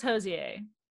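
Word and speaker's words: Hozier: No, it's pronounced Hosier.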